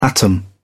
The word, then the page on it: atom: British English